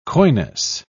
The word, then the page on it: coyness: British English